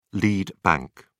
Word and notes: lead bank: British English

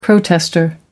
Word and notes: protester: American English

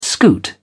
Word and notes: scoot: British English

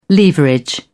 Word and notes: leverage: British English

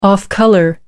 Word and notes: off-color: British English

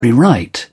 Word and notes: rewrite: British English

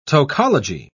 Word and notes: tocology: British English

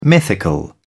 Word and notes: mythical: British English

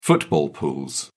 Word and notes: football pools: British English

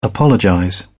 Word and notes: apologize: British English

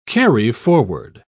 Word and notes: carry-forward: British English

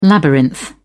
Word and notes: labyrinth: British English

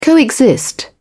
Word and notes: co-exist: British English

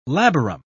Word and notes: labarum: British English

